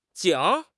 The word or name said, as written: 井